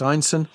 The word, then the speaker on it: Ineson: And if you're wondering about the spelling, Einstein is I-N-E-S-O-N, pronounced Einstein.